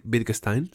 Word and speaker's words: Wittgenstein: esto, Wittgenstein, Wittgenstein, sí.